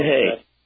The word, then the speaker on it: Hoeh: I would recommend that It's pronounced Hay, but it's spelled H-O-E-H. – Gotcha. – Herman Hay.